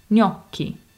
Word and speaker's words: gnocchi: Yeah, so the last syllable in gnocchi is spelled C-H-I. So the C plus H is pronounced as a hard K sound. Gnocchi.